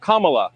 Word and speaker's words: Kamala: that will serve you and your fellow um, hosts on Fox her name is pronounced comma like the punctuation mark Look, kamala okay, okay.